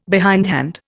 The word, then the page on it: behindhand: Indian